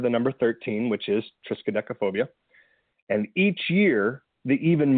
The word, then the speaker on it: Triskaidekaphobia: I actually watched a little YouTube to learn exactly how to say it correctly. So Triskaidekaphobia. – I'm scared of that name. – Yeah, Triskaidekaphobia.